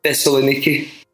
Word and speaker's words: Thessaloniki: Thessaloniki.